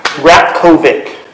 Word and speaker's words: Ratkovic: Ratkovic.